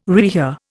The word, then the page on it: rehear: Indian